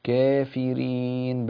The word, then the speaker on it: كافرين: كافرين بين التقليل.